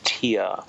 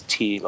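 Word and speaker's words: Týr: There seems to be much controversy, but it does appear that the most widely used pronunciation is tear. And the reference they use is tier, like tiers of a building or something like that.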